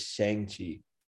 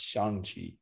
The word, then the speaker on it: Shang-Chi: Everyone in English says Shang-Chi. It's actually pronounced Shang-Chi, but nobody's going to say that because, you know, we don't try to, nobody really like thinks of it that way, but truth be told, that's how it should be pronounced.